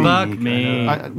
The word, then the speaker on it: Buck Meek: Buck Meek.